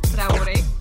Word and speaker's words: Traore: It's actually pronounced Traore. So, if you want to make your tongue do that, go ahead.